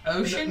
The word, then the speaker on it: Oshend: I literally just type random letters and O-S-H-E-N-D. – like the ocean? – Yeah, I get that a lot. – Is this spelled ocean? Ocean? – No, O-S-H-E-N-D. Ocean.